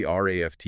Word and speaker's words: RAFT: What is the RAFT framework? – Sure. So RAFT is an acronym, RAFT, and it's pronounced just like the boat, raft.